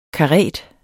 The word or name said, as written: karet